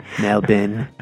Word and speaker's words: Melbourne: all based in Melbourne. That's how you say it in Australian. In Melbourne.